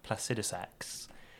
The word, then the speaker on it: Placidusax: So people haven't found him or haven't beaten him, and this is Dragon Lord Placidusax. – Say that again. – Dragon Lord Placidusax. – Say that again. – Dragon Lord Placidusax. – How to spell it? – Placidusax. So, Placid. Okay. Usax.